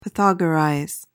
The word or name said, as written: pythagorize